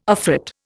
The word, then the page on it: afrit: Indian